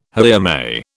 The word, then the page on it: haere mai: British English